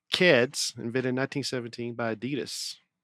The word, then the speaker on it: Adidas: Adidas. That's how they say it over there.